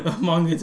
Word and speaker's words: manga: Surprisingly enough, you pronounce manga correctly.